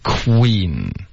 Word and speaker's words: queen: Okay, queen, 这个 w 的音要注意啊，是 k w queen.